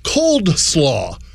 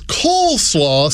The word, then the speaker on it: coleslaw: Like, Tom, I always pronounce the side dish as cold slaw. because it's a slaw that's served cold. – Acceptable. – Then a few years back, my best friend informed me that it is coleslaw, C-O-L-E, not C-O-L-D, like I'd been foolishly ordering at Popeyes for the last 30 years.